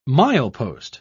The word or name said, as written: milepost